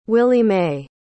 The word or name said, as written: willie mae